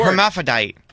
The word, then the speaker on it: hermaphrodite: A hermaphrodite. – A what? – I mean, I don't know if I'm even pronouncing it correctly. Hermaphrodite. – Uh-huh, yeah. That's right.